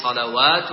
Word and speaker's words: صلوات: وهكذا صلوات، فعليهم صلوات من ربهم، لا تقرأها فعليهم صلاة من ربهم.